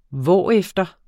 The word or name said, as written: hvorefter